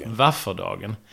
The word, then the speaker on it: vårfrudagen: Våfrudagen uttalas i vissa dialekter som vafferdagen.